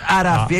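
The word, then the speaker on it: Arafi: Tem um interino agora, um Ayatollah interino, que é o Alireza Araf. Me corrija a... – Araf, é isso mesmo, é isso mesmo. Sua pronúncia do Farsi é perfeita.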